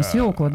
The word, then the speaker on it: свекла: Да, не чаще всего, так правильно. Свекла, да, действительно свекла.